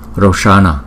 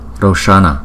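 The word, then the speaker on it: Roṣaṇa: Roshana, Roshana, Roshana. Chữ S có dấu nặng chúng ta đọc là Ch, Ch, Ch.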